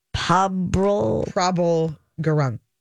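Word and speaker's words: Prabal Gurung: It's Prabal Gurung.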